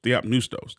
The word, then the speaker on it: Theopneustos: theopnestos. Nustos. Theopnustos. That's what it is.